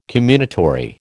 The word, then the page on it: comminatory: American English